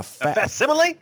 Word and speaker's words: facsimile: I think it's called a faxile or something like that. – A facsimile? – A facsimile? I don't know what it is. You know, wrong accent on the wrong syllable, something like that.